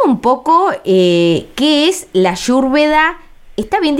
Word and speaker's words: Ayurveda: Ellos la Y la pronuncian como sería Ayurveda, no Ayu, como hacemos nosotros, que hacemos el Sh, sino sí, que Yu, como Ayurveda.